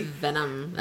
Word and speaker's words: Venom: You look over and Venom's laying beside you. – Did you just call him Venomin? Like cinnamon with a V? – Venom.